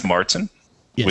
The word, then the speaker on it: Märzen: So it's an Oktoberfest Märzen, yes, with the umlauts that I don't know how to Märzen text. It's an A, long A sound. That's what the umlauts mean.